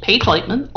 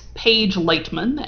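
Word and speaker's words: Paige Leitman: So you can find me, Paige Lightman, on Twitter, at Paige Lightman, and that's spelled PAIGELEITMAN.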